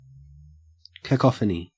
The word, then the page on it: cacophony: Australian English